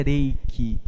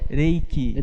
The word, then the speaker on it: reiki: Ai, a pronúncia correta é reiki. Como que é? Reiki. Reiki. Reiki. R em japonês tem som de R fraco em português.